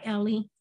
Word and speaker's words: Ellee: I go by Ellie, which is E-L-L-E-E.